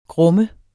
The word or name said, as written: grumme